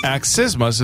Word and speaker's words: accismus: The M-U-S has got to be right. – Yes, that is. Axisthmus is a noun. It is spelled A-C-C-I-S-M-U-S.